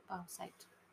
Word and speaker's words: bauxite: B-A-U-X-I-T-E. I know, right? Pronunciation. There we go. – Pronounce it to me, Google. – Bauxite. Yeah. – Bauxite? Oh. – Bauxite. – Yep. Yeah. Cool.